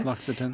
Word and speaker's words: L'Occitane: L'Occitane. Am I saying that right? L'Occitane, you know? – L'Occitane.